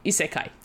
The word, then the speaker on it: Isekai: Isekai.